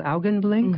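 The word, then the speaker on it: Augenblick: The album, it comes from a CD called Augenblink. Mm-hmm. Is that how you say it? – Yeah.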